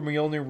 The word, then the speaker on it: Mjolnir: Brings the God of Hammers to Nidavellir, to the forge where Mjolnir was created.